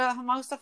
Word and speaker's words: Homosassa: Well, let's go to Homosassa, Florida. Homosassa. H-O-M-O-S-A-S-S-A.